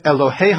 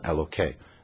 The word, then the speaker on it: Elohe: Hasiru, Samuel tells the people, remove, et Elohe ha-nechar. It's not referring to God, so you don't pronounce it Elokei. That would be a disrespect to God.